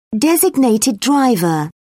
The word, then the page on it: designated driver: British English